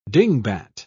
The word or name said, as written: dingbat